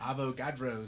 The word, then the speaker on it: Avogadro's: Is that how you pronounce that? – Avogadro's. – Avogadro's.